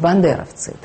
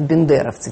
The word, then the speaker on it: бандеровцы: И вы посмотрите, что главное ругательное слово сейчас по отношению к Украине – это ведь бандеровцы. Причем так возникает, значит, это самое тогда возникшее ошибочное. У нас бывают такие устойчивые языковые ошибки, потому что бандера произносить почему-то как-то легче говорить бендеровцы, тем более, что город есть Бендеры.